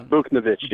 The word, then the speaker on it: Buchnevich: We still haven't signed Pavel Buchnevich. I can't say his name. I know his first name is Pavel. – It's Buchnevich.